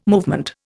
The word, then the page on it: movement: Indian